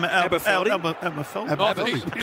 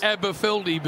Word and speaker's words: Aberfeldy: Aberfeldy.